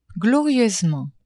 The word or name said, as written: glorieusement